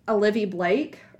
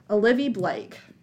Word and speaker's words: Olivie Blake: I think it's Olivia Blake or Olivie Blake. No, it's like just with like an E. Olivie, I'm pretty sure. – Oh. Yeah, Olivie Blake.